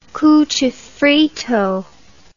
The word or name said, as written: cuchifrito